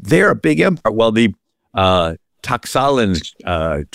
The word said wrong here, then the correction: Tlaxcalan: tribe that had been persecuted. And I know I'm not pronouncing that right. Tlaxcalan, D-L-A-X-C-A-L-A-N.